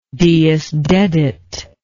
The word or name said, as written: Deusdedit